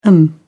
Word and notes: m.a: British English

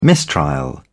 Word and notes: mistrial: British English

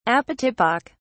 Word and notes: apotypic: British English

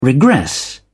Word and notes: regress: British English

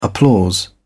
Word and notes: applause: British English

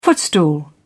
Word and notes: footstool: British English

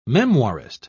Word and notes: memoirist: British English

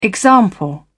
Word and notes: example: British English

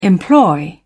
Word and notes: employ: British English

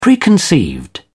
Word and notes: preconceived: British English

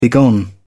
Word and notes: begone: British English

